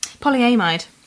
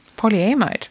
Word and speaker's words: polyamide: Polyamide. – Polyamide.